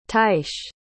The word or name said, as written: tyche